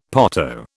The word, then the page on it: potto: British English